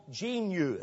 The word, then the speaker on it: gnu: I heard about an animal called Genu. It's spelt G-N-U.